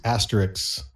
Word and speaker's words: Asterix: I've not read these Asterix sidekicks. Am I pronouncing that right? – That's correct.